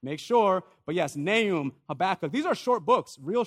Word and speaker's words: Habakkuk: Everybody say Nahum, Habakkuk. Oh, no. Habakkuk. Get it up, right up in there.